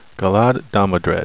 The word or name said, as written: galad